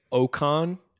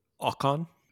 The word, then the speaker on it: Ocon: I say Ocon, and I think you're supposed to pronounce it Ocon.